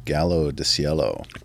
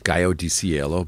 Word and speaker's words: Gallo di Cielo: Okay, so your second batch of tunes, my friend, begins with Ian Tyson and Gallo di Cielo. – Gallo di Cielo, but that's okay. Good try.